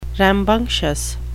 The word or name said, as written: rambunctious